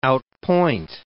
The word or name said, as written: outpoint